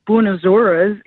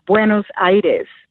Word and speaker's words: Buenos Aires: And a little correction on the Argentina is not Buenos Aires, it's Buenos Aires.